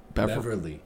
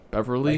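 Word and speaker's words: Beverley: How do you say his last name? – Beverly. – Beverly? – Like Beverly Hills? – Beverly, okay.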